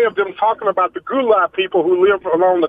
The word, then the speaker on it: Gullah: Gullah or gula is the way it's pronounced.